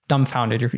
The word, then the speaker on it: dumbfounded: But when it comes to speak, you're kind of dumbfounded. You don't know what to say. – That's a great word you use there. Dumbfound. Can you say that again? – Dumbfounded. Yeah. Silent B.